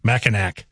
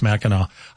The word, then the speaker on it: Mackinac: And I think with the way it's spelled, I think if you're not from there, you're going to mispronounce that. Mackinac. Right? It's not. I know. But if you're from Michigan area, you're like, oh, don't say it that way. It's Mackinac.